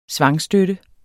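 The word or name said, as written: svangstøtte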